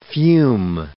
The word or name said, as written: fume